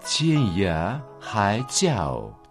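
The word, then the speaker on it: Тянья Хай Цяо (天涯海角): Тянь читается первым тоном, означает «небо». «Я» читается во втором тоне, значит «край». «Хай» – третьим тоном, значит «море». «Тяо» – в третьем тоне, означает «угол», «конец».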